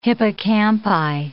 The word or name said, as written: hippocampi